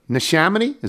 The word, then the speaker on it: Neshaminy: She says, "You went to Neshaminy? Is that how that's pronounced? – Sure.